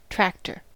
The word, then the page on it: tractor: American English